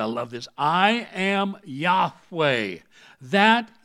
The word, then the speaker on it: Yahweh: L-O-R-D in verse 8 is all in capital letters. It translates the Hebrew term Y-H-W-H. We pronounce as Yahweh.